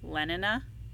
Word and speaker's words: Lenina: Lenina. Did I say that right? – You did, yeah. – Okay, good. Um, yeah, that's how they pronounced it in, in the audiobook, yeah.